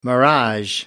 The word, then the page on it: mirage: American English